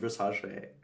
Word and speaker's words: Versace: It's actually pronounced Versace.